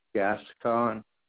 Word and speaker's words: Gascon: Michael Gascon.